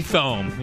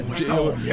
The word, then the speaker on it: Thome: Thome. – Yeah. – Thome.